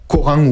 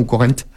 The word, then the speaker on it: Corent: La prononciation, j'ai peur de me tromper, je le dis un peu l'anglaise, Coran ou Corinthe. Voilà, C-O-R-E-N-T, où les archéologues s'activent énormément ces derniers temps, puisqu'ils ont fait beaucoup de trouvailles archéologiques.